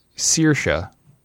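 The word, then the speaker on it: Saoirse: He also says it's pronounced Searsha, and I've been pronouncing it Searsha like a dick.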